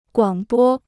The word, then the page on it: 广播: guǎng bō